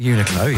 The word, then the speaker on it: Uniqlo: I've been to, is it Uniqlo? How do you say it? – Uniqlo, yes.